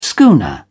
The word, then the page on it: schooner: British English